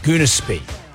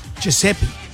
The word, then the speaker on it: Giuseppe: Because she'd never pronounced his name. – She'd just seen his name tag. – Yeah. – What was it? – Giuseppe.